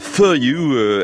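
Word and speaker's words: Fuh You: Ça s'appelle For You, F-U-H puis You. Hein, ça se prononce comme For You en anglais. Enfin, quand on parle vite.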